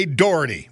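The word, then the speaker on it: Dougherty: Around here we say Doherty without the hard G.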